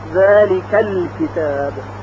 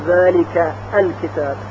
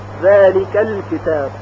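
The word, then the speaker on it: ذلك الكتاب: اگر الکتاب رو به کلمه قبل از خودش وصل کنیم ا که مفتوح هست ساقط میشه، ذالک الکتاب، یعنی نمیگوییم ذالک الکتاب، ذالک الکتاب. همزه وصل حذف میشه، البته در تلفظ.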